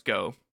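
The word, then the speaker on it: Gou: I think their name is pronounced Go. It's G-O-U.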